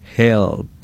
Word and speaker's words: HELLP: Básicamente es un acrónimo donde podemos utilizar la H, se escribe casi como si fuera ayuda, en inglés H-E-L-L-P, help.